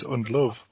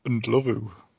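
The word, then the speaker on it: Ndlovu: Throughout his career, he was referred to as Undlove, when in fact, the pronunciation of his surname is closer to Undlovu.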